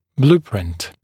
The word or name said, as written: blueprint